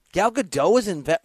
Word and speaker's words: Gadot: How do you say her name? Gadot? – I believe that's right.